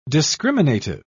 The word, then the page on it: discriminative: British English